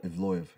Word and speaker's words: Ivloev: The same time, this guy, the Russian, Ivlov, if I'm pronouncing it right. – Ivloev, yeah.